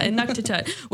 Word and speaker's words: Inuktitut: Inuktitut, which is her mother tongue.